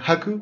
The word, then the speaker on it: Hak: Eh, se escribe H-A-K, pero aquí dicen Haku porque pues, nadie los va a detener y pronuncian la K como si fuera...